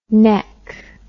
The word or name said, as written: nek